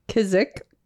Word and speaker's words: Kizik: Kizik. – K-I-Z-I-K.